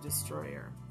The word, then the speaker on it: Distroller: And the company is Destroyer, but it's spelled D-I-S-T-R-O-L-L-E-R. So it sounds like Destroller, but it's they pronounce it Destroyer.